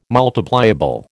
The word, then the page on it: multipliable: American English